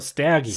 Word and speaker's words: Staggy: I thought you like mispronounced Shaggy. – No, Staggy.